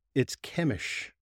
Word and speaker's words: Chemish: It's Chemish. – Chemish. – It is Chemish. So okay, the Chemish. Pronouncing guide in the back of the Book of Mormon, it describes as Chemish.